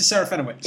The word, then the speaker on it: Serafinowicz: You know Peter Serafinowicz? I can't even pronounce his name. – Peter Serafinowicz. – That's it.